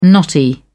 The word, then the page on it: knotty: British English